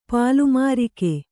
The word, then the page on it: ಪಲುಮೃಗ: palu mřga